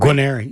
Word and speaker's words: Guarneri: And that was featuring Johnny Guarneri. Did I say that right? – Guarneri, yeah.